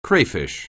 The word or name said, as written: crayfish